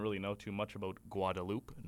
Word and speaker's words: Guadeloupe: Guadeloupe, not Guadalupe. We were pronouncing it Guadalupe because of the way a character in the movie Nacho Libre pronounces Guadalupe.